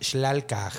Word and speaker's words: Xlalkaaj: Se escribe X-L-A-L-K-A-A-J. Entonces creo que es Shlalkaj.